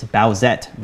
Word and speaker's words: Bowsette: It's Bowsette, by the way. That's how you say it.